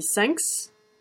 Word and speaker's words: thanks: Ou sinon, en version raccourcie, on peut dire aussi thanks, comme un 5 avec un S à la fin qu'on prononcerait.